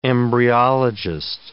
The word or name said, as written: embryologist